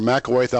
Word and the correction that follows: McElwain: But I think you hit it the first time and then messed it up the second time.